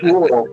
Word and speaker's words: rural: How many years it took me to be able to pronounce rural? It's rural, but thank God my father was from Baltimore and they had the Mid-Atlantic accent.